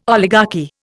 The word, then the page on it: oligarchy: Indian